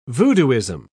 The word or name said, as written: voodooism